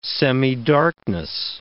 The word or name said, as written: semidarkness